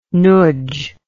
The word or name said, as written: nudzh